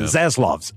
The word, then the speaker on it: Zaslav: Laszlov, I think is how you say his last name. – The new guy. – Zaslav. Z-A-S-L-A-V.